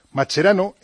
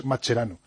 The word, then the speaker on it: Mascherano: Mascherano en español, o sea, en argentino es Mascherano. Si él hubiese nacido en Italia sería Mascherano.